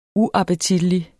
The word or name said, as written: UAPPETITLIGT